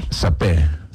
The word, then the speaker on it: saper: Yo creo que la R al final no se pronuncia, pero pues no sé. – Sapé.